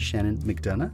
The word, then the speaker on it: Shannon McDonough: Joining me on this episode is a dear friend, Shannon McDonough.